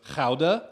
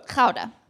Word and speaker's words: Gouda: But my favorite part of it the town where the train station was we had to get to was Gouda. – Gouda. – This is Gouda, for those of you who are... – It's spelled G-O-U-D-A.